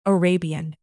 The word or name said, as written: Arabian